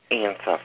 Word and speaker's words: Ein Sof: Ein Sof, yeah.